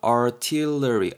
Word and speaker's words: artillery: artillery 여러분 발음이 약간 어렵죠. artillery, artillery 정도 되고요.